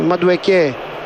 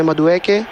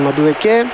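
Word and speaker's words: Madueke: Τον Νόνι Μαντουεκέ, και αυτός τονίζω στην τελευταία συλλαβή διότι έχει νιγηριανή καταγωγή. Πολλοί τον λένε Μαντουέκε, αλλά επειδή έχει νιγηριανή καταγωγή, ο μπαμπάς λεγόταν Μαντουεκέ.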